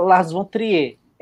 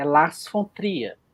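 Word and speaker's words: Lars von Trier: Todo mundo fala Las Fontrier. É Las Fontria.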